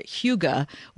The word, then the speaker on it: hygge: So, Jamie, first off, talk about this weird word, hygge, which is not, it's not spelled anything like that. It's spelled H-Y-G-G-E.